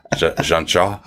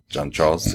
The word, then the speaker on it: Jean-Charles: He can follow in the footsteps of another French legend with the Spurs, Olivier Jean-Charles. Jean-Charles, I should say. Not Jean-Charles. He would not pronounce the S.